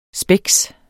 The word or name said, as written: spex